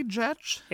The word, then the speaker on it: Jatz: Am I pronouncing that correctly, Jatz? – Yes, Jatz, like the cracker, yes.